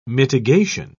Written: mitigation

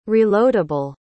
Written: reloadable

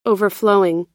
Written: overflowing

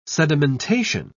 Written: sedimentation

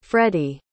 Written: freddie

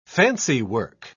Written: fancywork

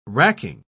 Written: racking

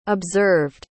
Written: observed

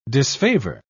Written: disfavor

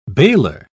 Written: bailer

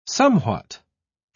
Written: somewhat

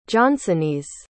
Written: johnsonese

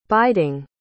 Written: biding

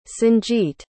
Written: sanjeet

